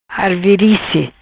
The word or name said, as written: Arvirice